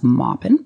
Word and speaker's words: Maupin: He's from North Carolina, so it's pronounced Maupin.